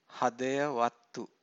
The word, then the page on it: hadayavatthu: Pali